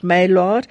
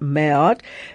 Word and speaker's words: Maillard: I've heard a lot of chefs calling it Maillard. Actually, it's a French word and it should be pronounced Maillard.